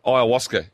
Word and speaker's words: ayahuasca: Um, ayahuasca. So I'm being told ayahuasca. You, rhymes with Oscar.